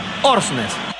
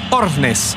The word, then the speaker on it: Aursnes: Frederik Aursnes. – Aursnes. – Aursnes. Creo que la U no se menciona.